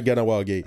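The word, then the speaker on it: Kahnawake: Kanawake, je le prononce pas aussi bien que toi. C'est pas Ganawake. Comme ça que ça se prononce, hein? Ganawake? – Ouais, Ganawake. – On m'a comme un G au début.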